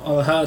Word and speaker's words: Olha: Olga Olha itu sebenarnya yeah. pengucapan aja bedanya kalau Olga bicara bahasa Rusia kalau Olha bicara bahasa Ukraina tapi sebelum Olga kan ada si Igor.